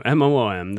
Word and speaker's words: Moom: So it's it is pronounced Moom. M O O M.